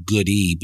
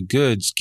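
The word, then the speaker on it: Goode: You know, it's funny because my mother's maiden name is Good, G-O-O-D-E. A lot of people mispronounce it Goody,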